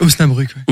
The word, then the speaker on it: Osnabrück: C'est Osnabrück.